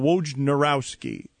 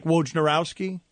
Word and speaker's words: Wojnarowski: Like Wojnarowski? – Yeah. How do you say it? – Try. – Wojnarowski? – The N is right after the J.